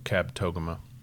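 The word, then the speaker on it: Kabetogama: Is that how you say it? – Mm-hmm. I figured it out finally. – Did you? – Yeah. Yeah. When you start, was it phonetically on the sign of the lake? – That it should have been. But once you start, all the locals, because they'll correct you. – Oh, yeah. – So Kabtogama.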